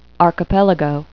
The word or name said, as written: archipelago